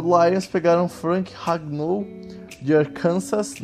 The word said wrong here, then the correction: Arkansas: não, mentira, Arkansas, tem que ter a pronúncia certinha. A Kansas é, é tem, tem origem francesa e os franceses dão a entonação na última sílaba da palavra.